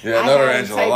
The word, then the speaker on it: Notarangelo: Yeah, Notarangelo, long Italian last name. That's Notarangelo. – Okay. – It's like Michelangelo, except Nota. And Derek is spelled? – D-E-R-E-K.